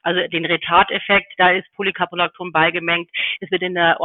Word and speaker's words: Polycaprolacton: dass der Filler Polycaprolacton enthält. Ich hoffe, ich habe das jetzt richtig ausgesprochen. – Super richtig ausgesprochen.